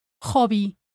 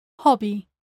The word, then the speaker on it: hobby: And there are indeed a few words in Spanish with a non-silent H. But they are not really an exception, since the Spanish pronunciation rules do not apply to foreign words, and those words tend to come from English. For example, hall, hall, hobby, hobby. But remember that that's just a handful of words.